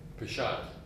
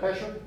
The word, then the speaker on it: Peshat: I think the word that you want for the P is Peshat. Peshat. – Oh, Peshat instead of Pesher? – Yes.